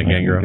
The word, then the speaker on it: Gangrel: Yeah, Gangrel.